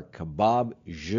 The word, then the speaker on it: Kebab Je: John emails, the best Mediterranean restaurant in Charlotte is by far Kebab Je.